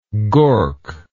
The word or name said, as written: gork